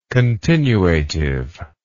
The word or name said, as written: continuativeness